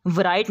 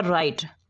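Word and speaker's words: write: इसके बाद है साइलेंट डब्ल्यू, वराइट नहीं पढ़ते, डब्ल्यू जो है ये साइलेंट हो जाता है, इसको प्रोनाउंसिएशन में अदा नहीं करते, तो ये आएगा राइट।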